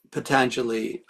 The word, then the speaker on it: Patanjali: Well, does anyone know what the correct pronunciation is of Patanjali? Or that's it. – Is that it? – That's it. Patanjali, I've heard, you know, Patanjali, but no, I've Patanjali, I think, is a more correct pronunciation.